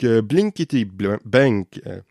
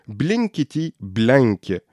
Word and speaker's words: Blinkity Blank: Donc, Blinkity Blank, j'ai mal prononcé ça. Blinkity Blank,